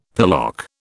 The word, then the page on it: pillock: British English